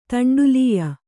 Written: ತಂಡುಲೀಯ

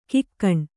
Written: ಘಕ್ಕನೆ

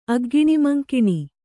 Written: ಅಗ್ಗಿಣಿಮಂಕಿಣಿ

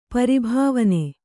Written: ಪರಿಭಾವನೆ

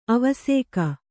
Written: ಅವಸೇಕ

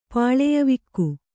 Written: ಪಾಳೆಯವಿಕ್ಕು

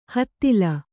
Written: ಹುದಲು

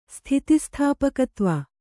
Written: ಸ್ಥಿತಿಸ್ಥಾಪಕತ್ವ

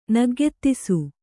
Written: ನಗ್ಗೆತ್ತಿಸು

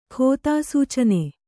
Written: ಖೋತಾಸೂಚನೆ

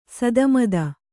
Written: ಸಿತಮತಿ

